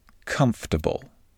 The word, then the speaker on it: comfortable: The word comfortable is often mispronounced. Many non native English speakers pronounce it with four clear syllables for bull. However, most native English speakers pronounce it with only three syllables and an emphasis on the first syllable comfortable.